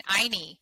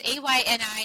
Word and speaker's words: Ayni: Yeah, it's living in Ayni. It's A Y N I, and it's pronounced Ayni, and it's a Quechua word from the Q'ero people.